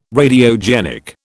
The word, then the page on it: Radiogenic: British English